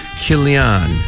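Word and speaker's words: Chilion: 말론을 메일란 이렇게 발음하시고 기련은 킬리안, 킬리안, 메일란, 킬리안 이렇게 연습해 주시기 바랍니다.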